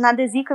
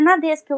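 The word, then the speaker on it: Nadesico: Eu tava falando Nadesika e vocês, é Nadesca.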